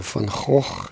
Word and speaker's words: Van Gogh: And then Van Gogh comes along, or Van Gogh, that's the way it should be pronounced.